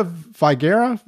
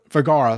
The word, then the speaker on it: Vergara: Sofia Vergara. Vergara, I'm sorry. I'm sorry, Sofia. I mispronounced that.